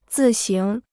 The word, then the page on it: 自行: zì xíng